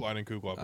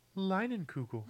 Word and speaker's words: Leinenkugel: Tell us how to pronounce your name. – Schnischenpfaffer? – Or is that really how you say it? Leinenkugel? – Leinenkugel, I believe. – Oh, okay. – Alexa, how do you say Leinenkugel? – Leinenkugel. – I knew you were going to do that. – You heard her. – Leinenkugel it is.